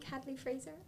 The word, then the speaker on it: Hadley Fraser: I think Hadley Fraser. Hopefully I said his name right. I did.